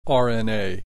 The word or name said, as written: RNA